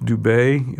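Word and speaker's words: Dubé: My name is Richard Dubay. Looks like Doobie, but it's pronounced Dubay. You think of cafe or attaché or resume. They have an E that has an accent at the end.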